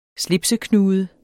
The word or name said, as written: slipseknude